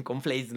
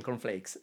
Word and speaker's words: cornflakes: lo digo con conflace lo más feo posible. – Suena, filosófico. Ajá. – Este. déjame, lo pronuncio bien, cornflakes.